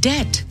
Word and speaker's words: debt: And the word debt is spelled D-E-B-T. The B is silent.